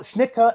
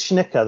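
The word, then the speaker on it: Schnittke: You say Schnitke? Is that the? – Yeah. – I've heard it pronounced both Schnicka and Schnicke. I've heard it both. I think it's Schnicka though, is how it's pronounced.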